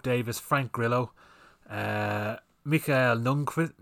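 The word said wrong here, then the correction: Nyqvist: Lundqvist, you say his fucking name, or Michael Nunquist. I can never say his name right. – Nyquist. Nyquist? You know who the fuck I'm talking about.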